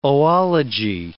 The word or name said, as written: oology